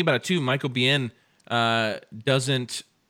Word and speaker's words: Biehn: In this, because I do think Michael Biehn's good. Did I say that right? I'm so sorry. – Yeah, I'm like really eddy about that now. But Michael Biehn's good.